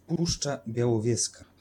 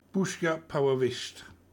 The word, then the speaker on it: Puszcza Białowieska: Puszcza Białowieska. – There we go. Puszcza Białowieska.